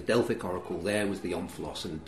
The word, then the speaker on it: omphalos: I pronounce it omphalos.